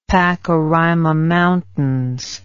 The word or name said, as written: pakaraima mountains